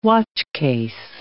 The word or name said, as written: watchcase